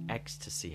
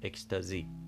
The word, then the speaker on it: ecstasy: اومده ولی خب بر صورت انگلیسی نیست تلفظ این واژه در زبان انگلیسی اکستسی هست پس بذارید یک بار دیگه برم رو اون فرمول همیشگی آنچه ما میگوییم اکستازی آنچه آنان میگویند منظورم انگلیسی زبان ها اکستسی آنچه میتوان گفت اکستسی